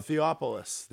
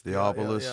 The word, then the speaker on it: Theophilus: I can't think of how you say Theophilus London. I can't pronounce the dude's name. – Oh, Theopolis. – Theopolis.